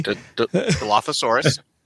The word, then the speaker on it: Dilophosaurus: I am sorry for mispronouncing that. – Dilophosaurus. – Okay. Dilophosaurus, yeah.